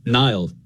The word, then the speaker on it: Niall: When I was growing up, I would say Nile, like N-I-L-E effectively, like the river.